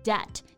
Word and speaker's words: debt: Debt, D-E-B-T 表示欠债、债务这个意思。要注意啊，这里的字母 B 是不发音的。